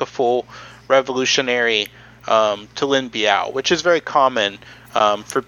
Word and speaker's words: Biao: So they're basically saying that my boy Lin, how do you say his last name? I'm gonna say Biao all the time unless you say it correctly. Biao.